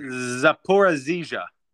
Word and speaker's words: Zaporizhzhia: Zaporizhzhia. How's my pronunciation in Ukrainian? – Zaporizhzhia.